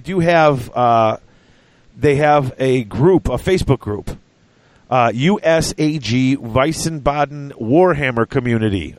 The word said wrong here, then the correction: Wiesbaden: I don't know if I pronounced that right. – It's just Weissbaden. – Oh, sorry, Weissbaden. So It's WIESBADEN Warhammer Community on Facebook.